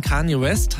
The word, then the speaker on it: Kanye West: Nach der Trennung von Kanye West habe ich es richtig ausgesprochen? – Ja.